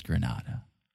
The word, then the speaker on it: Granada: Spanish is Grenada. – She, just to be clear, Nicole was saying Grenada. – Yeah. – I think it's Granada. – I mean, that would be the Spanish pronunciation.